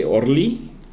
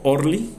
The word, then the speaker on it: Orly: Estamos hablando que Orly, o Orly, como le quieran decir, de todas las formas está, que lo pronunciación está bien dicho, ¿sí?